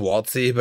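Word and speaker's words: Wxltty: So, Jaro TV, Waltz, I think it's pronounced Waltzy, but it's W X L T T Y, and then it's E M T E E.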